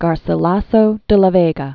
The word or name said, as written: Garcilaso de la Vega